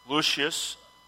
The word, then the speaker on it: Lucius: Lucius, and that's the correct pronunciation for that, by the way,